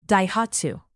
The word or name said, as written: daihatsu